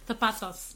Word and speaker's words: zapatos: Because it's a Z and I pronounce it like a Thursday. – Zapatos.